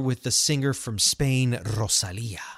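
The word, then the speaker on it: Rosalía: Rosalia. I understand they call you Rosalia.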